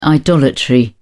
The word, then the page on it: idolatry: British English